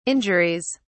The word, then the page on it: injuries: British English